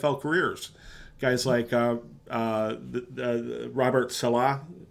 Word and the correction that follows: Saleh: is that how you pronounce it? Salah Saleh. Um, you know, is there any buzz that this guy, uh, Jonathan DeCoster, is uh, an up and comer at all?